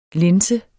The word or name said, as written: linse